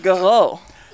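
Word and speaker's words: G'Reaux: It's pronounced Garo.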